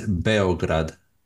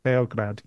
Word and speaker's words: Beograd: There's a few, I mean, I guess the anglicized way is Belgrade, but in Serbian it's Beograd. – Beograd, yeah.